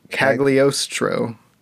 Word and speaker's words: Cagliostro: The Castle of Cagliostro, I think. – Wait, hold on. – Cagliostro. Yeah, I think yeah. – that was, yeah. – Yeah. Like Stromboli.